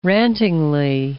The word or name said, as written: rantingly